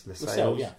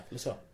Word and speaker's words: Lascelles: And James Lascelles, how do you pronounce that? Lascelles. – Lascelles.